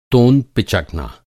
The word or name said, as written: तोंद